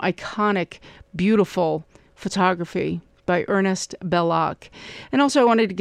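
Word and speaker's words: Bellocq: Also, somebody called saying that they remember seeing those photographs by Ernest Belloc, and that Belloc is spelled B-E-L-L, let me see, O-C-Q, Belloc is how it's French, in the Aperture magazine.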